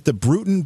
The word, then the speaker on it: Bruton: Daniel, I want to find out more about the Bruton, B-R-U-T-O-N, Parish Church?